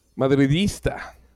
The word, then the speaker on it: madridista: Oh, pues yo creo que la afición madrilista, ¿madrilista o madrileña? ¿Cómo se dice? – Madridista.